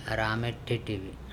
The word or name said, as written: arame THeTHe